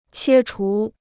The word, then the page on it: 切除: qiē chú